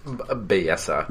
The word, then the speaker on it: Belleza: Belleza. – Ooh. – Oh, so you pronounce it like kind of Spanish.